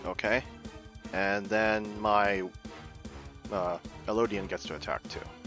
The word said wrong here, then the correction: Eidolon: Eidolon, by the way. I think it's how it's spelled. Thank you. I actually didn't know how to say it. So it's Eidolon? Okay. Eidolon.